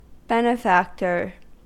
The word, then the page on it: benefactor: American English